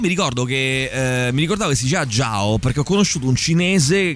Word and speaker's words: Zhao: E mi ricordavo che si chiamava che contrariamente al fatto che si traslitterava con la Z, si pronunciava con una sorta di G, quindi Zhao.